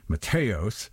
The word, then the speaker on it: Matheos: The European pronunciation would be Jim Matheos, of Fate's Warning.